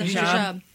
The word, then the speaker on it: hijab: Is that, am I saying it right? – Hijab. It's a hijab.